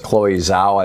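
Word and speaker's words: Chloé Zhao: Chloe Zhao, I believe is how her name is pronounced, put the movie together, directed the movie, just brilliant job.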